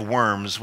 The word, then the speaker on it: Worms: You know, I think there's that diet of worms, which is, the Germans pronounce a W as a V, Worms, so it's the diet of worms.